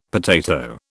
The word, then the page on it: potato: British English